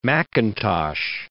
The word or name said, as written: McIntosh